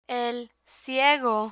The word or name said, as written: el ciego